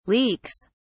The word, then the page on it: leaks: British English